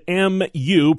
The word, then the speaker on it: Mu: Complicating COVID concerns is the emergence of this new Mu variant, spelled M-U, pronounced Mu.